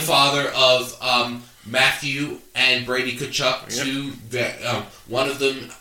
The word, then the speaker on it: Tkachuk: Tkachuk doesn't spell that way. It looks like I believe. – Yeah, not at all. The T is silent. So yeah. It's Keith Tkachuk.